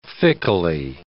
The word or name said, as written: fickly